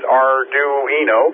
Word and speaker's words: Arduino: The Arduino, and excuse me, I was mispronouncing it last week, and Jim kept trying to correct me. It's Arduino. The Arduino group of stuff, which actually interfaces into many things.